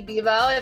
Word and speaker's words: Bebout: Lindsay Bebo, if you remember me from the elusive Space Jam podcast.